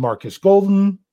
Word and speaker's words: Marcus Golden: Then the Steelers went out and signed Marcus Golden.